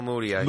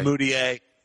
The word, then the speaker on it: Mudiay: You know, they got nice guard play from the rookie, who I always botch pronouncing his name, Emmanuel Mudiay. – Mudiay.